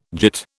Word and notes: git: British English

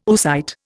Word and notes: oocyte: Indian

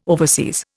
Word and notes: overseas: Indian

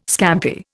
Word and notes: scampi: Indian

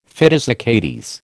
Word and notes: fidus Achates: American English

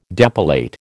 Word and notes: depilate: American English

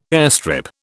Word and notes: airstrip: British English

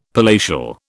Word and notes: palatial: British English

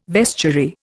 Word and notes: vestiary: Indian

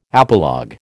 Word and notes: apologue: American English

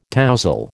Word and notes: tousle: American English

